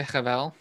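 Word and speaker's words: hevel: habel. Is that it? – I would say Havel, but Havel. You're good.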